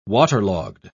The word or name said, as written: waterlogged